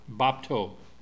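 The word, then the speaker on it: bapto (βάπτω): But the little table with two legs, that's the P, then the T, and then the thing that looks like a W is the long O sound, bapto.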